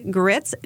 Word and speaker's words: Gritz: Or you can just Google my name, Tracy Gritz, T-R-A-C-E-Y, Gritz, G-R-I-T-Z, like the food here in the South.